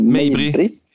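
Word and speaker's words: Mejbri: Hur uttalar man hans efternamn? – Ja, Mabry.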